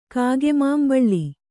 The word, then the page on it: ಕಾಗೆಮಾಂಬಳ್ಳಿ: kāge māmbaḷḷi